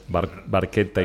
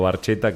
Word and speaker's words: Barchetta: Porque por eso es Barchetta y no Barchetta, que pobre Gary Lee no lo pronunció bien.